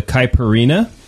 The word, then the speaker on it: Caipirinha: Okay, so what we're drinking is called a Caipirinha.